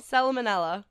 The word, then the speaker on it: salmonella: Not salmon. Did you just say salmon manella? – Salmonella. – Salmonella? – Salmonella. – Isn't the L... – It's too early for this. – The L is silent, I believe, okay?